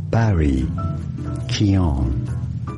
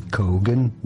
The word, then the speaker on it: Keoghan: Many say Kogan. That's not correct at all. Barry Keoghan. Barry Keoghan. And now you know more. – Barry Keoghan is how you pronounce it. – Yeah, it's like the G is silent.